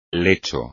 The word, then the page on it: lecho: espagnol